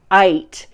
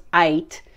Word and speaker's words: eth: And so that the Hebrew word for with you in this verse is ait, which is E-T-H, it's pronounced ait.